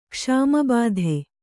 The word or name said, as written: ಕ್ಷಾಮಬಾಧೆ